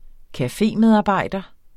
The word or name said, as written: cafémedarbejder